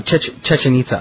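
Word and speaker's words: Chichén Itzá: Chichen Itza? – No, Chichen Itza.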